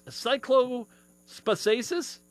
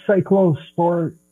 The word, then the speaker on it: Cyclosporiasis: Cyclospaciasis? – Cyclosporiasis.